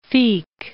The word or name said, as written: Feke